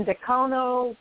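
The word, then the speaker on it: Dacono: It's going to be another 150-lap race, and this time it's going to be at Colorado National Speedway in Decono, Colorado. Did I say that right, Cal? – Yeah, that's close enough.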